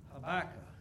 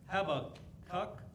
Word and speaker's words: Habakkuk: Habakkuk. As I said last week, some people pronounce it Habakkuk.